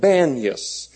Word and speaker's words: Banias: Actually, it was called Panias, but because the Arabs can't pronounce P's, they call it Banias.